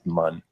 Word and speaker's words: Muun: Oh, well, as far as evidence that would say this is not Snoke, he's a Mun. MUUN, or is it immune? I don't know how to pronounce it. I've always said Mun in my mind. It's how it's pronounced.